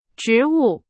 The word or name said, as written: 植物